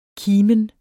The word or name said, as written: kimen